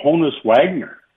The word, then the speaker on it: Honus Wagner: Now, listen, his boss, former boss, Bruce McNall, and maybe Gretzky bought a Honus Wagner, if I pronounce that right. – Yep.